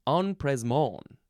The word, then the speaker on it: empressement: Next is No. I added a syllable. Empressement. But it does have the M there in the second letter. It is spelled EMPRESSEMENT. Empressement. It's a French word, of course.